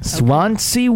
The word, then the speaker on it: Swansea: Swansea, Wales.